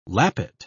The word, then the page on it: lappet: British English